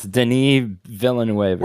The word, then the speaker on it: Villeneuve: How do you say his name? Villeneuve. – I believe so. I believe it's Denis Villeneuve.